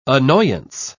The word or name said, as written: annoyance